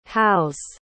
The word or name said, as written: howes